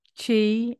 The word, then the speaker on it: Qi: Qi, and Shen.